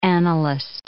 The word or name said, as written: analyst